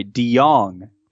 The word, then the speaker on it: DeJong: DeJong. DeJong is how Baseball Reference says to pronounce it.